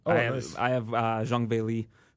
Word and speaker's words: Zhang Weili: Zhang Weili. Who, if people are looking at me crazy when I pronounce him like that, but that's how her team told me it's pronounced.